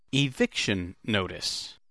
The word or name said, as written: eviction notice